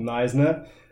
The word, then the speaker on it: Knysna: I come from a tiny ass little town on the coast called Knysna, which is spelled very strangely.